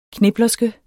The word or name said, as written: KNIPLE